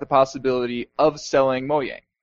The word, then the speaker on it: Mojang: I'm calling it Mojang because that is how you pronounce J's in Swedish.